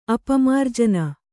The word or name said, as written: ಅಪಮಾರ್ಜನ